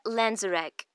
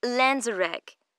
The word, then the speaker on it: Lanzerac: Maria Lanzarek. – Sorry. Could you spell that for me? – Yes. Lanzarek. L-A-N-Z-E-R-A-C.